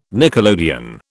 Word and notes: nickelodeon: British English

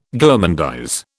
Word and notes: gourmandise: British English

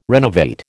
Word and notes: renovate: American English

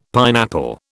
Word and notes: pineapple: British English

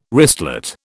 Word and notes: wristlet: British English

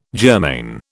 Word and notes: germane: British English

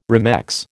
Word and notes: remex: American English

